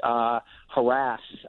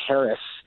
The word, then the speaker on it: harass: I mean, you know, you look at the word now is scandally pronounced harass. A couple decades ago, it was almost only pronounced Harris. And over time, the harass pronunciation became more and more prominent. And by now, it's the more favored pronunciation.